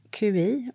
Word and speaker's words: qi: Så vad QI och uttalat qi, det är livsenergin.